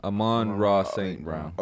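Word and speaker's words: Amon-Ra St. Brown: Amon St. Brown. Did I say that right? – Yeah. Amon Ra St. Brown.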